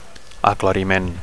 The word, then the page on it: aclariment: Catalan